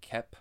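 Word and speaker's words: Koepp: But yeah, you mentioned the writing, which it was written by David, I can never pronounce his last name right, Kep, I believe it's called. It's K-O-E-P-P. I always want to keep saying it Coep, but I don't think that's right.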